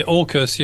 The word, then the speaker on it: AUKUS: You and Alexander Gillespie co-authored an article urging caution to New Zealand's approach to the AUKUS security pact. – Yes. – Am I pronouncing that right? – Yeah, AUKUS, yes.